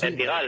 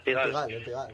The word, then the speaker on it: Pigalle: ¿La Pingallé? ¿Cómo se pronuncia? Es que yo no sé. – ¿El qué, perdón? – El barrio este francés chungo. – El Pigalle. – El Pigalle.